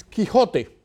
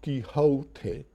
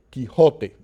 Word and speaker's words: Quixote: The way to pronounce it in modern Spanish is Quixote. Quixote. No gliding of the O. Not Quixote, Quixote. In Spanish, you don't glide the vowels. They're short and crisp.